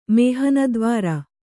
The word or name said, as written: ಮೇಹನದ್ವಾರ